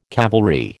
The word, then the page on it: cavalry: American English